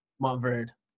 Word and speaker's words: Montverde: Montverde.